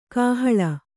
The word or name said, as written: ಕಾಹಲೆ